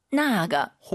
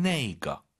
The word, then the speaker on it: 那个: 나가. – 혹은 이렇게도 발음해요. 숫자 1을 집어넣어서 나 이거의 빠른 버전 네이거.